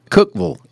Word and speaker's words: Cookeville: Cookeville. – As the locals say there.